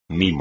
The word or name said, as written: mimo